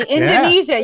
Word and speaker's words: Indonesia: That's Indonesia. – Okay. – You said it correctly. Indonesia.